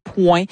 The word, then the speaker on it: pwen: Quand elle dit Nan Pwen la vie Anko, entre autres, le Pwen, je devrais le prononcer point.